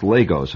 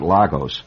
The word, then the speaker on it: Lagos: And by the way, it's pronounced Lagos, and not Lagos.